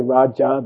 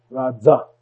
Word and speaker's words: Raja: There is no J in Sanskrit. It's a Z. They don't say Raja, they say Radza.